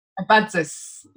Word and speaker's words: Abadzis: Abadzis.